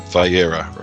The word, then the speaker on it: Vayera: And this week, now, how do I pronounce this? Is it Vayera? – Vayera, right. – Vayera, okay.